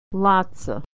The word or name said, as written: lotsa